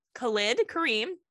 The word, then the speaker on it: Khalid Kareem: I want to make sure I say his name properly because it's spelled like Khalid. It's spelled like Khalid. We hear it all different ways. Khalid Kareem.